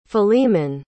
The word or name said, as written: Philemon